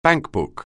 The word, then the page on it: bankbook: anglais